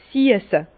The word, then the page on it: 's siesse: Bas-Rhin; Herrlisheim